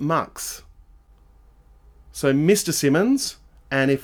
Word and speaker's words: MX: How do you pronounce the title? Mux. So it's MX.